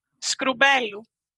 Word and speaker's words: Skroumpelou: Wait, how are you saying it? – Skroumpelou? I don't know. – Mm-hmm. Yeah, some Greeks even fail to pronounce it correctly. So yeah, that's gotta. – Can you say it again? Say it again, so I can kind of cement it. – Skroumpelou. – Skroumpelou. – Yes.